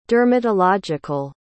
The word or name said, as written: dermatological